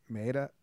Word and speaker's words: Maeda: Maeda. – They had Maeda behind him.